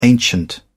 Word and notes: ancient: British English

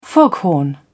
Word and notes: foghorn: British English

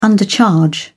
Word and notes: undercharge: British English

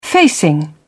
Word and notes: facing: British English

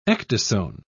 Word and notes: ecdysone: British English

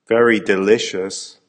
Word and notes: very delicious!: American English

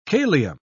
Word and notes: kalium: British English

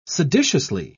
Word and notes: seditiously: British English

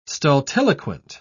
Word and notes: stultiloquent: British English

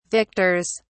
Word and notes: victors: British English

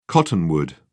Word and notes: cottonwood: British English

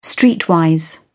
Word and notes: streetwise: British English

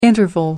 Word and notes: interval: American English

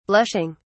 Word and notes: blushing: British English

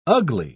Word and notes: ugli: British English

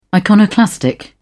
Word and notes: iconoclastic: British English

